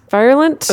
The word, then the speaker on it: virulent: Virulent? – It's like virile. – Not like virile. No. – Like a virus. – Like a virus. Yes, virulent.